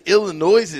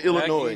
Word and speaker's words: Illinois: So he says, does anybody there have the balls to tell the captain that the S in Illinois is silent?